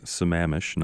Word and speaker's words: Sammamish: We know how to pronounce Sammamish, not Sammamish, and Puyallup.